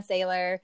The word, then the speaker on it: Saylor: So on Instagram, it's just McKenna Saylor. My name is spelled kind of weird, M-E-K-I-N-A, and then it's S-A-Y-L-O-R.